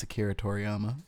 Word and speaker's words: Toriyama: It's Akira Toriyama. – Thank you. Akira Toriyama. – Hey, you said it right. – Akira Toriyama.